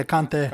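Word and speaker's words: Kanté: That defender, Kant. Is that how you say it? – Yeah, Kante.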